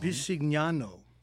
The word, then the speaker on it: Bisignano: The president appointed Leland Dudick, a manager in charge of Social Security's anti-fraud office's acting commissioner, while Frank Bisignano, I'm probably saying that wrong. – Bisignano. – Thank you.